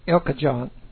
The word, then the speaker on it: El Cajon: It was a college that Tim LaHaye founded in Southern California, down by Los Angeles, San Diego, El Cajon, El Cajon. Some people don't know how to pronounce it.